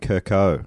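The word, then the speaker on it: Kerkow: Do you think I could be the next Kelvin Kirkle of Australian bowls? – If you have said his name wrong. Damn it, Kelvin Kirko.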